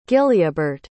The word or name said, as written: gilleabart